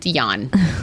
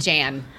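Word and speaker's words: Jann: Except his name is pronounced Jan. It's not Jan.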